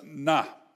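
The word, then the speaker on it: na (נָא): And it is a little bit of language which we would pronounce in English as na.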